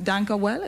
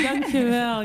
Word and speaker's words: dank u wel: Dank u wel. Am I saying it right? – Yeah, you're saying it right. Dank u wel.